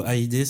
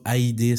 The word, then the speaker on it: Hajde: Le mot AID, c'est le nom du média. – AID AID, H-A-J-D-E. – H-A-J. E. – J-D-E, ouais. Et le J se prononce Y.